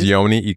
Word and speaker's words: Yoni: My name is Yoni Ikoto. By the way, there's a lot of people who think my name is spelled with an E at the end, but it's not. It's Y-O-N-I space E-K-O-T-O.